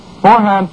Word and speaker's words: Forhan's: Four-hand toothpaste is sold at all drug, department, and 10-cent stores. It's spelled F-O-R-H-A-N-S. It's pronounced four-hand.